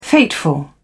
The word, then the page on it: fateful: British English